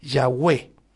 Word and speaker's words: Yahweh: Las transcripciones griegas indican que YHWH, que es el tetragramatón, el nombre impronunciable de Dios, debería ser pronunciado como Yahweh.